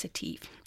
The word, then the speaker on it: Etive: I'm hoping I am pronouncing that one right, actually. It could be, yeah, I think it's Etive.